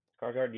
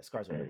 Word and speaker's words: Skarsgård: I always mess up his last name, but Bill Skarsgård, I think that's how you pronounce his last name. – Skarsgård, yeah. – Skarsgård, my bad.